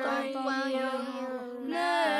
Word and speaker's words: تطيرنا: تطيرنا تطيرنا How many syllables? Ta. – Ta. – No, the first one is ta. – Ta. – Toy. Yar. Na.